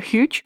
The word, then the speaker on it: huge: произносить прилагательное huge, опуская первую согласную.